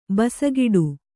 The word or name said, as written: ಬಾಸೆಗೆಡು